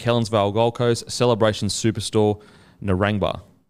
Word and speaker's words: Narangba: really Narangba.